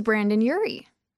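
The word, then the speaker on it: Brendon Urie: You brought up Brandon Urie. – Yes. Yeah. Lead singer. – Am I saying that right? – Yeah. Brandon Urie, Panic at the Disco.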